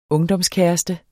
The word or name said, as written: ungdomskæreste